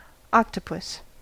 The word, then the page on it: octopus: American English